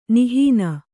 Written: ನಿಹೀನ

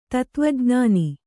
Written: ಧಾತುವಿಜ್ಞಾನ

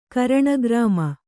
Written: ಕರಣಗ್ರಾಮ

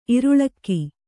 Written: ಇರುಳಕ್ಕಿ

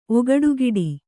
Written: ಒಗಡುಗಿಡಿ

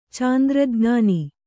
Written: ಚಾಂದ್ರಜ್ಞಾನಿ

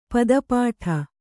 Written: ಪಾದಪಟ್ಟ

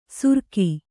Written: ಸುರಕಿ

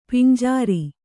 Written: ಪಂಜರ

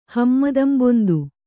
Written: ಹಮ್ಮದಂಬೊಂದು

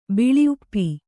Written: ಬಿಳಿಉಪ್ಪಿ

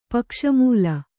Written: ಪಕ್ಷಮೂಲ

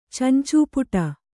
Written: ಚಂಚೂಪುಟ

